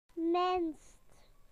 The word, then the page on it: least: British English